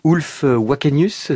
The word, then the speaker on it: Ulf Wakenius: Vous serez même en direct sur France Musique à 23h avec un camarade musicien qui vous accompagne depuis des années, Ulf Wakenius, c'est oui.